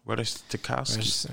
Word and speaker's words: Ratajkowski: Ratajkowski.